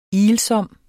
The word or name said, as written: ilsom